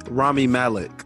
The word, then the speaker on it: Rami Malek: Remy Malek. What was his name? – Rami Malek. – Yeah, Rami. Yeah, before we butcher that name.